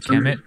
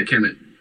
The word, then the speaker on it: Kemet: How do you say that? Sons of Kemet. Yeah, Kemet.